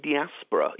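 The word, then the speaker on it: diaspora*: It's one that I believe we're pronouncing diaspora, even though they spell it with a little asterisk at the end of the name. I think that's silent.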